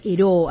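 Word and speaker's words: Ayrault: se escribe Irao, pero se dice Heró, a sus esposas, obviamente el piloto del avión, ¿no?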